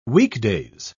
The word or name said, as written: weekdays